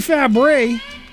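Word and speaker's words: Fabares: Well, that Shelley Fabre. Yes, and that's how it's pronounced.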